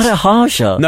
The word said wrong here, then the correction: Maharaj: No, Lala Maharaj. – Do you think he's made that up? – Luke Lala hyphen Maharaj.